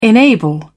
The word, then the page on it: enable: British English